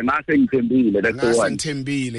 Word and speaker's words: Ngase Ngithembile: My pronunciation might be a bit off. Ngaze Ngithembile. Did I say that right? – Yeah. Ngase Ngithembile. – Yes, most definitely.